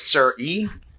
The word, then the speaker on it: Siri: No, I clicked Siri. Just go away. – Push the home button. Well, I ain't able to go away. I'm doing the transaction. It is pronounced Sir E.